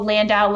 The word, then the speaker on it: Landau: Nicole Landau, last name is spelled L-A-N-D-A-U.